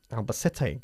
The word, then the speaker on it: Albacete: Albacete doesn't receive as many tourists as Seville does. – Albacete receives fewer tourists than Seville does. – Albacete. Good. I liked your pronunciation.